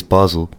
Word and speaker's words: Basel: I think that's what they said on Marco Estrella was interviewed before the game and said it is Basel because we never know what the pronunciation is.